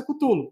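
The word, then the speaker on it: Cthulhu: Então eles falam, como a gente quer que os jogadores saibam pedir o jogo na loja, a gente vai adotar a pronúncia Cutulo. Então tá lá, assim, com as letras e o cara explicando. Fala, olha, vai se chamar Call of Cutulo. Tá? – Então é Cutulo, não é, é Chulo, é, assim, Então é Cutulo.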